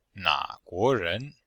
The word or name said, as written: na3guo2ren2